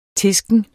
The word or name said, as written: tisken